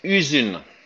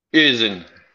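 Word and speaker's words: Hüzün: That's And I'm going to try and pronounce this, but track six on the album, Huzuen? – It's Usun. – Usen. – Usun. – Yeah, that goes back to how British we are of any of that pronounce. Just say the letters as you see it. – It's a Turkish word.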